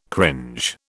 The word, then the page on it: cringe: British English